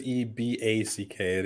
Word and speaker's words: Eback: It is E B A C K.